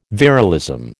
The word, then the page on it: virilism: American English